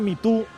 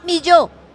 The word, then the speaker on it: Mitú: Fuera de Mitú, un municipio del Baupés. – Mi yo.